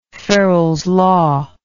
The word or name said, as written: ferrels law